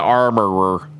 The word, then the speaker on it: armorer: The armor. – Yeah, the armorer. You gotta use all of those syllables in there. Armorer.